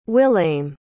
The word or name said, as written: willaim